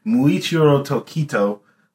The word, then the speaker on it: Muichiro Tokito: It's Muichiro Tokito.